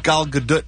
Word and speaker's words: Gal Gadot: And you can't sell short the amazing performance of Gal Gadot, who is... – Is that how you say her name? – Yes.